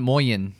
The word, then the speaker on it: Moyenne: I would say Torrefaction Moyenne. – Yeah, that's way better. – Let me try. – It's like cayenne.